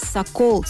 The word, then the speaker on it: сокол: Это бревно называлось сокол с ударением на второй слог.